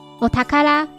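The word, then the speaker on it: お宝: 念作 OK，第二个 R 是 rich 的 R，最后的 O 是日语中的宝藏的 O，念作 Otakara。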